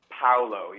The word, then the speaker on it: Paolo: Am I saying your name correctly? – Paolo, yeah.